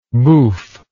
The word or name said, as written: bouffe